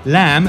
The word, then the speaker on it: lamb: Lamb has got a B at the end. Written, but it's not pronounced. It's pronounced as lamb.